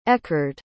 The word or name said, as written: eckerd